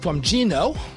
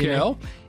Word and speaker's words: Geno: This is from Gino, pronounced, spelled G-E-N-O, okay. – Gino.